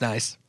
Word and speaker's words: Nice: So is Nice. – That's nice.